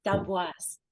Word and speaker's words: D'amboise: Did I say that right? Damboise? – Nope. Damboise.